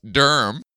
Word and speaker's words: Durham: Today, I am in Durham, North Carolina, which I just found out is pronounced Durham. – Yeah, there are no vowels. It's just D-R-M.